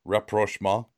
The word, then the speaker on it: rapprochement: And perhaps with time, there can be a rapprochement between the two. And how was my pronunciation there, by the way? – Good.